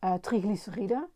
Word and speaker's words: triglyceride: Ehm, dat noemen ze triglyceriden.